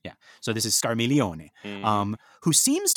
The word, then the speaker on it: Scarmiglione: It's Scarmiglione. Mm-hmm. So I think that is Scarmiglione. – Yeah. – Right? You don't say the G, right? – Right.